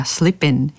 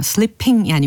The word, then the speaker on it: Slippin': Slippin', 예, Slippin'이라고 들렸습니다. Slippin'이라고 끝부분 발음을 마치 N 사운드처럼 내고 있어요. Slipping이 아니고 Slippin', 이렇게 Slippin', 네, 이처럼 확인을 하셨습니다.